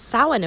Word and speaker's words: Samhain: And it's read or spelled as Samhain, but it's Samhain apparently. I've been saying it wrong for a while.